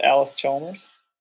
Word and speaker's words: Allis Chalmers: Uh, Oh, that's how you pronounce it, Allis Chalmers.